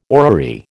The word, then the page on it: orrery: American English